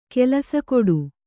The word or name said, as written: ಕೆಲಸಗೇಡು